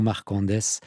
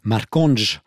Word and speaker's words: Marcondes: Marcondes. Marconge, si on prononce correctement, c'est important, par le quatuor Topazio enrichi de la contrebassiste Gabriela Negri.